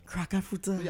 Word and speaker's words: crack a fruta: Break time, you know, they crack a fruta on in the school. – That's how you say it, boy. – What's that? – Crack a fruta.